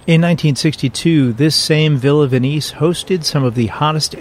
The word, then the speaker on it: Venice: Today's topic is Villa Venice, spelled like Venice, Italy, pronounced Venice like Denise,